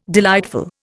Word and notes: delightful: Indian

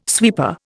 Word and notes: sweeper: Indian

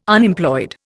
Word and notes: Unemployed: Indian

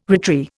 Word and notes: retry: Indian

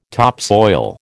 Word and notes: topsoil: American English

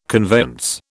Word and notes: conveyance: British English

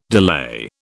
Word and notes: delay: British English

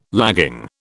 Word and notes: lagging: British English

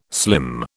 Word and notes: slim: British English

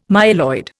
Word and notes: myeloid: Indian

